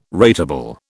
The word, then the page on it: ratable: British English